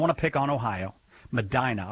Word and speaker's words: Medina: If it's supposed to be pronounced Medina, I apologize. I never get those right. – Yeah, it's pronounced Medina. – Nah! – Medina. – Yeah, like a normal person, but not a person from Ohio.